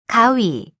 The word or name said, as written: 가위